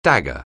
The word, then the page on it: dagger: anglais